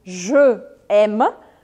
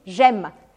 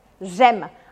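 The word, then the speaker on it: j'aime: Por exemplo, ao invés de dizer je aime, você não consegue pronunciar isso rapidamente. E por isso você acaba dizendo gema, gema.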